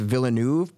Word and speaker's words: Villeneuve: The reason why Dennis, I keep his last name is pronounced Villeneuve, but I for some reason I can't keep saying that, so I'm just gonna say Dennis because it sounds cooler.